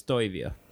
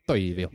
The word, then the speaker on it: Toivio: The proper way to say it is Toivio. – Toivio. – You got to take the Swedish out of it to get the finish right.